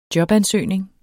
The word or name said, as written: jobansøgning